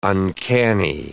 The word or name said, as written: uncanny